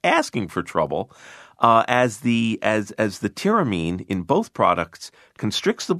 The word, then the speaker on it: tyramine: Yes, tyramine.